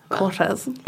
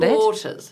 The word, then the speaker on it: Cortez: Cortez. – Is that not Cortez. – It? – Cortez. – Oh, you said it right. You just said it in your beautiful RP accent.